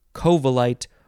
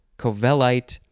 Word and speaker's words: covellite: You can emphasize the first syllable, covellite, or you can emphasize the second syllable, covellite, spelled covellite.